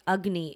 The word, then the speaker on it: Agni: So our conversation needs to start with Agni, and that's spelled A-G-N-I, but the A takes the U sound, Agni.